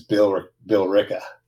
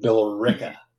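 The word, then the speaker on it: Billerica: And then the one that'll really fuck you up, though, is Bill Ricka. – Yes. Yes. – Because that doesn't even look like Bill Ricka. – Bill Ricka. – It's spelled Balerica. – What? – But it's pronounced Bill Ricka.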